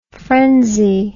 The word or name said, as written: phrensy